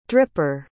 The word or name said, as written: dripper